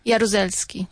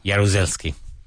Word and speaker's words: Jaruzelski: Jaruzelski. – Jaruzelski. Ask her if she remembers him. – Do you remember General Jaruzelski? – Jaruzelski? – Jaruzelski. – Jaruzelski. – Do you remember General Jaruzelski? – Yes, I do, a little.